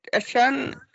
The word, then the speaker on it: Achane: Ja, det är Achane säger man nu i NFL. När han spelade på Texas A&M så var det alltid Shane.